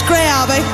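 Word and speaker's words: Greyabbey: Gray Abbey. Did I say that right? – Yes, Gray Abbey.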